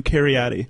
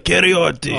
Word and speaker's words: Carioti: Americans do Cariotti. – Okay. – I'm American. – How do Italians do it? – No, my grandpa does it like Cariotti.